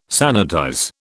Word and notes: sanitize: British English

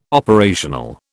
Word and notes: operational: British English

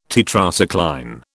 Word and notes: Tetracycline: British English

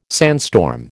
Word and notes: sandstorm: American English

